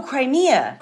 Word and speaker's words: Crimea: Not Croatia, but Crema, C-R-E-M-I-A. Crema. – Oh, Crimea. – Crimea. Yes. I knew I was getting it wrong. Crimea.